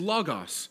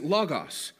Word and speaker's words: logos: And the Greek word here is logos, or logos, depending on how Western you are in your pronunciation. But the word is logos.